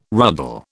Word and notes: ruddle: British English

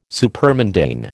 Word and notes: supermundane: American English

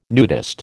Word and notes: nudist: American English

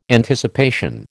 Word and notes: Anticipation: American English

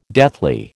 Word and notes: deathly: American English

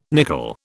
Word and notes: nicol: British English

